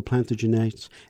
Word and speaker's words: Plantagenet: Is it Plantagenet or it depends on part of the country? – Now we tend to say Plantagenet, but of course you're closer in your pronunciation to the original,